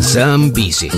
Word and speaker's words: Zambezi: But we'll head to the news now with the Piranhas and Zambezi. Which rhymes with lemon squeezy, but...